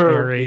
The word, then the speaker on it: Aries: I'm an Aries. Yeah, I knew you were. – You still said it wrong. It's just Aries.